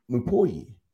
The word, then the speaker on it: Mupoyi: I don't know, that's not how you pronounce it, but it's M-U-P-O-Y-I. Mupoyi. – Mupoyi is how I've been saying it. Yeah. We'll go with that.